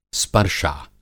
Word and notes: स्पर्शा: Hindi

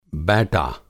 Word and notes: बैटा: Hindi

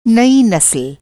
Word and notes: नसल: نسل

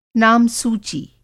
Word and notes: नामसूची: Hindi

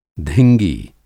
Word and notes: धिंगी: Hindi

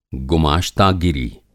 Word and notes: गुमाश्तागीरी: گماشتہ گیری

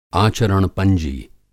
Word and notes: आचरण-पंजी: Hindi

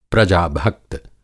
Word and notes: प्रजा-भक्त: Hindi

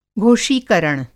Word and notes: घोषीकरण: Hindi

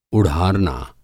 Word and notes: उढ़ारना: Hindi

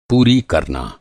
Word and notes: पूरी: پوری

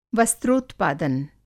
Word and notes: वस्त्रोत्पादन: Hindi

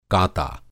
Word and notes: काता: Hindi

